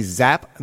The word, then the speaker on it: Xapp: Advertising technology company Zapp Media, which is spelled X-A-P-P Media, creates ad products for streaming audio, and the company released a new edition of its ongoing study of streaming audio advertising.